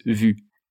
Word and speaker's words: Vue: And Vue is Vue. So that's pretty wrong, I'd say.